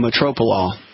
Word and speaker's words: metoprolol: One is clonidine, one is hydrochlorot, one is metrolol. I can't pronounce it. Metroprolol. – It's called metoprolol.